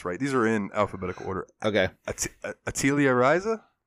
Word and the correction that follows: Atelier: Atelier Yes.